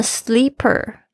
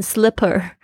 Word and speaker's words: sleeper: 这边的 sleeper 大家要特别注意一下，不要念成 slipper。